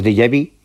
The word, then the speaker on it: déjà vu: lo que llamamos déjà vu, que eso, pues, mira que bien lo pronunciaba. – Sí, muy bien, muy bien. Déjà vu, sí.